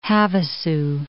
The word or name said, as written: Lake Havasu City